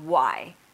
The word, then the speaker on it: WAI: It's called Why, pronounced as in why are we here? But it's written WAI, stands for We Are Infinite.